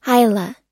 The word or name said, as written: hila